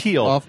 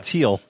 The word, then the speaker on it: Thiel: Teal. – Teal.